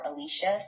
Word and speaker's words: Alecia: I can be reached on Instagram at Nicole, which is spelled Nikol underscore Alicia, spelled Alecia. And on Facebook, it's just my name, Alicia Swoop, Alecia.